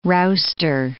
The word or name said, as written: rouster